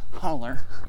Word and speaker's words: hollow: Or as I'm told, it's properly pronounced, holler.